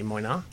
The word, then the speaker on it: Moynat: I went into this store called Moina. Have you ever heard of Moina? – How do we spell that? – M-O-Y-N-A-T, Moina. – I don't know that. – And Moina is this amazing French company.